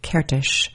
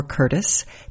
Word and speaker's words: Kertész: Today on Write the Book, you're going to hear a conversation with the author Joseph Kertész, or Curtis, depending on how you pronounce it. He's Hungarian. They say Kertész there, but I believe in Canada he goes by Curtis.